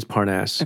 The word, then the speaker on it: Parnas: Is that how you say his name? I never know. – So there has been this discussion about it. – Okay. – And apparently it is Parnas. – Okay, Parnas.